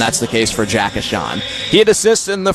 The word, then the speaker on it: Ahcan: His last name is spelled A-H-C-A-N, pronounced Ashon, from Savage, Minnesota, USA.